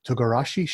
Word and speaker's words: togarashi: It's two maple glazed fresh mochi donuts with karage fried chicken topped with I hope I say it right. Togarashi slaw. – Oh, you got it.